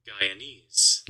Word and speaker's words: Guyanese: Guyanese.